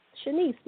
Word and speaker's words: Shaniece: It's Shanice, and it's spelled just like it's pronounced. My aunt named me, so it's S-H-A-N-I-E-C-E, Shanice, because I'm her first niece. Last name is Bennett, B-E-N-N-E-T-T.